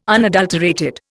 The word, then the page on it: unadulterated: Indian